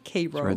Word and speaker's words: Cairo: It begins at Cairo, Illinois, which is spelled like Cairo, but pronounced like Cairo.